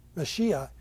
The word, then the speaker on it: Mashiach: Mashiach is how the Hebrews, even today, I believe, would pronounce that word.